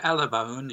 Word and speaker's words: Alabone: Yeah, Alabone, yes.